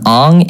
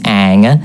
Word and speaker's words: Aang: You went for Aang, it's Aang. It's a double A.